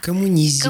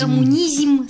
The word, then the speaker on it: коммунизм: Многие слова, оканчивающиеся на «изм», как мы помним, в Советском Союзе произносились... – С мягкой буквой. – Да. – Коммунизм. – Коммунизм, социализм и прочие другие интересные вещи.